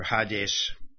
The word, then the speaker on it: Hades: the or Hades, they don't pronounce the H in Greek, "shall not prevail against it."